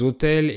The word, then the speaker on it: autel: Le chœur est la partie de l'église où il y a les autels et les reliques. Un autel, c'est un mot qui a la même prononciation qu'un hôtel avec des chambres pour dormir, mais là je parle d'une table sur laquelle on pose des offrandes religieuses.